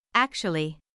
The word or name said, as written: actually